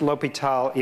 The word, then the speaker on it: L'Hôpital: That's the same name, since the circumflex is what you put in French to omit the S. So it's the same thing, and it's still pronounced L'Hôpital, even if it's got an S in it.